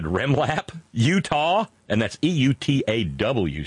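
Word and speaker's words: Eutaw: Utah. That's E-U-T-A-W, Utah. – Yeah, that's how you pronounce it, Ohio.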